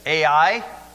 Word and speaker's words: Ai: In chapter 8, they were told to go against that town that you spell it every time you say it, Ai.